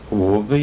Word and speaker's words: Hove: Hove, eller så sier du det? – Hove, ja, sier jeg. – Sier hove? – Ja. – En gang til. – Hove. Men enten uttalen er rett eller gal, de... – Nei, men den er rett den uttalen som er gjengsatt, som folk sånn til pleier sier det.